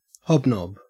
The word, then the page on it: hobnob: Australian English